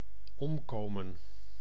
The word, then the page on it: passer: France (Paris); [pa.se]